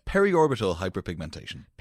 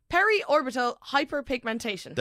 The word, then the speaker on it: periorbital hyperpigmentation: Okay, so the clinical term for dark circles is, as always, I'm going to definitely pronounce this wrong, but I'm going to give it my best attempt. Periorbital hyperpigmentation? – Periorbital hyperpigmentation. – Periorbital hyperpigmentation. – There you go.